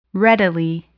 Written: readily

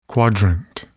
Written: quadrant

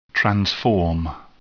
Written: transform

